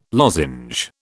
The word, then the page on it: lozenge: British English